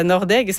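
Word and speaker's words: Nordegg: Par exemple, demain, je fais la fête franco à Nordec. Je sais pas si c'est comme ça que ça se prononce. – Oui, ben oui, c'est à Nordeg.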